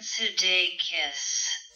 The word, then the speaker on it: Sudeikis: Well, there's not an A in it. – So, it's Sudeikis.